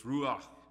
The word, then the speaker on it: ruach: In Hebrew, it's ruach. It's translated wind, breath, and spirit. I don't know if I'm pronouncing that correctly. R-U-A-C-H, ruach.